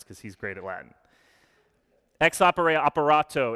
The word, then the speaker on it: ex opere operato: In liturgical theology, I'm not going to say this right, sorry, Max, there's a concept called ex opere operato.